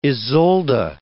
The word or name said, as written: Isolde